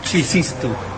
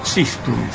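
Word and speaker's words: txistu: Chistu, se escribe con TX. – Chistu. – Chistu.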